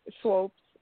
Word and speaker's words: Swopes: Swopes, and my first name is Jamila.